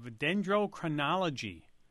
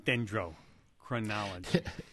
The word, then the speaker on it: dendrochronology: A professor of dendrochronology, University of Arizona in Tucson. What is dendrochronology? – Dendrochronology, yes. That's always a test of how well you can pronounce there, and you've done very well.